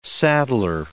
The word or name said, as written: saddler